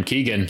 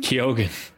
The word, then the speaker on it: Keoghan: Probably because you pronounce his last name Keegan. – Keoghan. – What? How is it pronounced? Keoghan? – Keoghan. – Nah, I'm not going to do that. – You can tell because there's an O there.